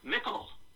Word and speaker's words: mickle: Word of the day is mickle. Rhymes with nickel and spelled very similarly. M-I-C-K-L-E.